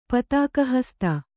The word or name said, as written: ಪತಾಕಾಹಸ್ತ